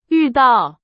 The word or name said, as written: 遇到